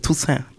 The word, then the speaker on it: Tusse: Men du, jag måste säga, du nailar uttalet. Tusse.